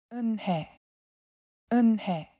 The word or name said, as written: Unhei